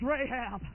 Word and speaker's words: Rahab: It said, and Boaz begot Rechab, which pronounced in its original form is Rahab.